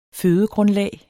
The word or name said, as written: fødegrundlag